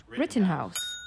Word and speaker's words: Rittenhouse: You know, people watching the fucking communists this week over the Rittenhouse or whatever the shit that kid's name is. – Yeah, you actually said it right. – It is it? Rittenhouse? – Rittenhouse.